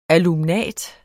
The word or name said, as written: alumnat